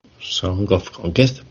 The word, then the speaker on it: Song of Conquest: También comentaros que aparte del top 10, al final del programa vamos a sortear un código riquísimo de Song of Contest. ¿Lo he dicho bien? – Song of Conquest, perfectamente.